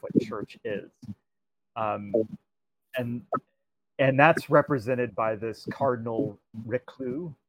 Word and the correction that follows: Richelieu: I believe his name is, or that's not how you say his name. I will forever mangle French names, because I'm awful at it. Richelieu, Cardinal Richelieu, who's basically thinks that this kind of bastion of tolerance is keeping the Catholic Church from fully, you know, fully enveloping France.